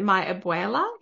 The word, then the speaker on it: abuela: Is it, do I pronounce it my abuela? Is that how you say mm-hmm.